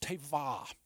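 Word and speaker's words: teva (תבה): But the word ark in the original language is pronounced teva.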